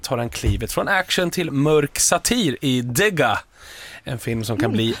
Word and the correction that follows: Digger: Eller är det Digger? – Det är Digger, men jag föredrog ditt uttal.